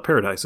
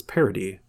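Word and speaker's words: Paradis: He was brainwashed into believing that by going over to Parody, another word I realized I was pronouncing wrong. It's not paradise, it's Parody, which is French for paradise.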